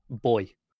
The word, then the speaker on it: buoy: I hate how Americans say that. It's one of my most hated American pronunciations. – How are you supposed to say it? – Boy. Because it comes from buoyancy.